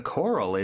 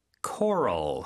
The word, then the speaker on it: coral: Coral, C-O-R-A-L. – 네, 그렇죠. Coral. 우리는 색깔을 얘기할 때 코랄색 이렇게 표현을 하는데 영어로는 coral 이렇게 발음하는 것도 우리가 익혀두면 좋겠네요. The coral is so colorful.